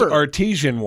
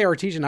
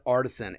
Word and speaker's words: artesian: And you say artesian. I've always in my head pronounced that artisan. And I know that's not the way it is, but go fuck yourself.